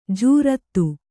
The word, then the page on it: ಜೋರತ್ತು: Kannada